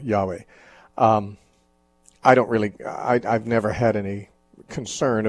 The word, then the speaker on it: Yahweh: I think most modern scholars agree that Yahweh is a much better, much closer approximation to the way it should be pronounced rather than Jehovah.